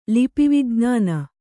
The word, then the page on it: ಲಿಪಿವಿಜ್ಞಾನ: lipi vijñāna